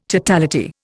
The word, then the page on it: totality: Indian